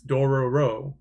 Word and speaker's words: Dororo: The series name comes from Tetsuga's childhood memory of his friends mispronouncing the Japanese word for thief, which is Dorobo. – Oh. – So it's Dororo.